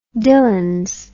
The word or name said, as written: Dillons